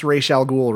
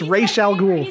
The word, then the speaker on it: Ra's al Ghul: They pronounced Ra's al Ghul right. – It's not Ra's al Ghul? – No, it's not. It's not Ra's al Ghul. It's Ra's. It's Ra's al Ghul.